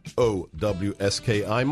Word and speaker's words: Lewandowski: That's LEWANDOWSKI. My engineer, Leroy, gave me grief yesterday because I used the original Polish pronunciation. It's Polish, okay? – It's true.